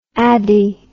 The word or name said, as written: addy